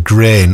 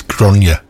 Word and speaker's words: Gráinne: Yeah, I pronounced it grain last week, because it's spelled G-R-A-I-N-N-E. Anyway, I got a text from Angie saying it's Gráinne.